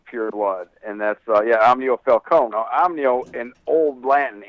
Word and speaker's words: Falcone: Would that be the correct pronunciation? – Falcone.